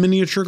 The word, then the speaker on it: miniature: Is it the same in miniature golf? – I have no idea. I don't think so. – Is it the same in miniature golf? – I like that you pronounce all of the syllables.